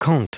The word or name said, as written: conte